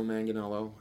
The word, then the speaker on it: Manganiello: So at Joe Manganiello. – That's how you pronounce it, by the way.